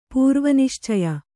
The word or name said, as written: ಪೂರ್ವನಿಶ್ಚಯ